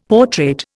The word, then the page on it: portrait: Indian